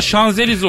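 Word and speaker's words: Champs-Élysées: Mesela Türkçe çıham tire Elyese yazıyor. Sonra Şanzelize okunuyor.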